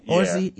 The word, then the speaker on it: Orci: And I think Chris talked about this on Sanity Check, but Roberto Orci, I think that's how you pronounce it. – Orci.